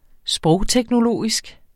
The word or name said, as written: sprogteknologisk